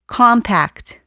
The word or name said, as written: compact